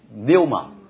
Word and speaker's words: pneuma: 啊，pneuma 零，pneuma 啊，也是个双母音 u，然后跟 new 拼在一起，pneuma，但是它拼在王的下面，pneuma，pneuma，但是它那个 p 不发音，只是给那个口型，pneuma，pneuma，pneuma，所以你是 p 的口型，pneuma 啊，闭嘴的，pneuma，p 没有音的啊，pneuma。只是给一个口型。